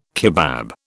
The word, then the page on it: kebab: British English